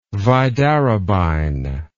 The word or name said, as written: vidarabine